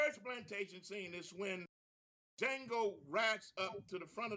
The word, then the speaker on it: Django: I'm sure all of you, if not, go check it out, have seen the movie Django. D, capital D, J-A-N-G-O. The D is silent.